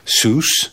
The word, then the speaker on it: Seuss: Mais c'est rapidement devenu pour tout le monde, tous ceux qui le lisaient, sous, parce que ça, ça doit rimer avec Juice.